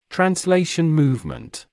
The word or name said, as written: translation movement